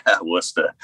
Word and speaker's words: Worcester: I'm chatting vocalist of High Command from Worcester. Is that how you say it? – Yeah, Worcester. Yeah, a lot of people Worcester. Get it wrong, but you nailed it, man.